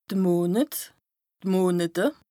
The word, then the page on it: de Monet, d' Monete: Bas Rhin; Strasbourg